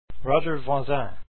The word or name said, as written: Voisin